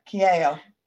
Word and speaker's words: Kyaio: Kyaio.